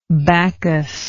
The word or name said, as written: bacchuslike